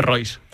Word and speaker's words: Reus: Hay que recordar que dentro de ese infrafútbol, llega al Elche procedente del Reus, cuando el Reus entra en toda la dinámica de problemas que se llevan por delante. – Ya que estáis corrigiendo pronunciaciones, creo que se dice Royce. – En el Royce.